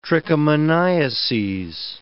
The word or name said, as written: trichomoniases